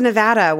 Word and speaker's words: Nevada: We're back to Nevada, where I can redeem myself because it is pronounced Nevada, not Nevada. Nevada.